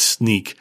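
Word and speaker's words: Snyk: It's a company that the spelling is S-N-Y-K. I often wondered, how do you pronounce that? But it's Sneak.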